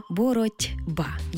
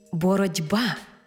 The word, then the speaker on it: боротьба: Але вимовляємо і чуємо це слово зовсім інакше. «Боротьба», бо дзвінкий приголосний «б» вплинув на попередній глухий «т» і зробив його дзвінким «д». Іншими словами, глухий «т» уподібнився під впливом дзвінкого «б» до «д».